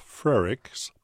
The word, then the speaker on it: Frerichs: On Keeping the Faith this afternoon, we're talking with Laura Frerichs. That's spelled F-R-E-R-I-C-H-S, Laura Frerichs, who's the director of the University of Illinois Research Park.